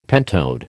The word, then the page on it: pentode: American English